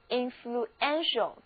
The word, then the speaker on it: influential: 注意这个时候字母 ue 并不再在一起发 u，而是分别发音， u 读作 u，是一个短的 u，而 e 则读作 a，重音呢也后移了，所以这个单词跟我一起来念一下，是 influential， influential。